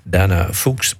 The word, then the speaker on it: Dana Fuchs: F-U-C-H-S. Maar als je dat dan opzoekt op in Wikipedia, dan staat er keurig netjes Dana Fuchs, pronounced as Fuchs.